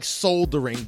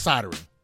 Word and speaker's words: soldering: Oh no, it's spelled like soldering, but it's pronounced soldering.